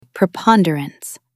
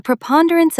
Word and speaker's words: preponderance: A preponderance of evidence tips the scales completely in your favor. Stress the second syllable, preponderance.